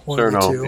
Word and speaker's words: Cerno: This is a 99-second Toho Caravan shooter featuring the character Kerno. Is that pronounced right? – Kerno or Cerno? – Cerno. Yeah, Cerno sounds better. Every time I was Kerno, all I can think of is the Kentucky Fried Chicken.